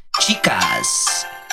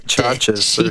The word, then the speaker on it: Chachis: That's not chicas. That's like chachas or something. It's C-H-A-C-H-I-S.